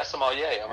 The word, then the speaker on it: sommelier: Well, in your bio, you mentioned you're also passionate about food, and you became a sommelier. That's how you say it, correct? – Yeah, sommelier.